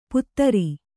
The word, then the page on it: ಪುತ್ರಿ: Kannada